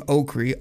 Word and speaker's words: Okrie: You guys can find me at Derek Okery. That's spelled D-E-R-E-K, the only way to spell Derek. Last name Okery, O-K-R-I-E.